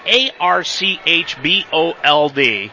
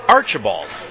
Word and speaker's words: Archbold: It's spelled A R C H B O L D. And I heard so many media members this past week, especially in Mansfield, pronounce it Archibald. There's no I and there's no A in the, in the second part of it. It's Archbold and I come from Northwest Ohio,